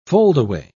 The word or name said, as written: foldaway